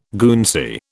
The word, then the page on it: Guernsey: British English